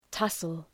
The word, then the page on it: tussle: ‘tʌsəl